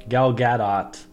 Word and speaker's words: Gal Gadot: Am I saying it right? Gal Gadot? Yeah. Gal Gadot.